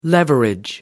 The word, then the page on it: leverage: American English